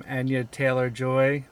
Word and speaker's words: Anya Taylor-Joy: Anya Taylor-Joy? – Yes.